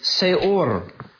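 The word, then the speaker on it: se'or: One of which is seor. Seor. If you're interested in spelling it phonetically in English, in just the Roman script, that'd be S-E apostrophe O-R to get that kind of stop in there.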